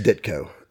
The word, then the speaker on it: Ditko: It's pronounced Ditko. – Ditko.